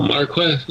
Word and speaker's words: marquis: Ooh, how do you pronounce that? Marquise? – I've heard it pronounced Marquis. – Marquis. – I don't think it's Are you sure? It doesn't sound right. – Well, I've also only ever seen it spelled this way. – Sounds made up. – Oh, senior to whatever that is. – Oh, Marquess, yeah. Marquis. – Well. – Oh, Marquis. – Well. – The Marquis de Sade, yeah. – Although the English form is pronounced Marquess, so.